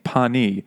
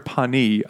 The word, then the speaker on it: Penis: Because I settled finally on my favorite, the surgeon in residence at the House of High Hopes, young Dr. Horatio Pawnee, spelled alarmingly, pronounced with Gallic gentility. Dr. Pawnee, I always introduced myself, P-E-N-I-S.